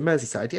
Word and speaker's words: Merseyside: So my biggest likelihood of percentage comes from, I want to say Merseyside. Does that, did I say that right? – Is it Merseyside? Yes. – Yes.